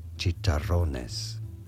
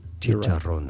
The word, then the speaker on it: chicharrones: In Spanish, this is said as chicharrones. Chicharrones. Right. Chicharrones. You don't want to stress on that ro syllable. And because there's a double R, you want to roll your R quite thoroughly.